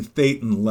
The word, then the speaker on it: thetan: And then you start moving up these operating thetan levels, T H E T A N, thetan it's, levels. Uh, it rhymes so, with Satan levels.